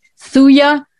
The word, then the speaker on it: Thuja: It's usually Thuja, T-H-U-J-A 30. Thuja, the J is silent. I'll pronounce it phonetically, Thuja. T-H-U-J-A, the second word is oxidotalis, but Thuja is fine enough, 30, and we often use it twice a day.